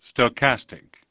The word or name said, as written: Stochastic